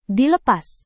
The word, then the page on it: dilepas: Indonesian